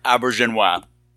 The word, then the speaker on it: Auberjonois: René Auberjonois. – Right. Yeah, how do you say his name? – I took a vac of Vicodin so I can say it properly. – Yeah, Auberjonois.